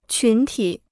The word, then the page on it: 群体: qún tǐ